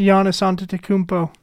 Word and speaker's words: Antetokounmpo: Oh, Giannis Antetokounmpo.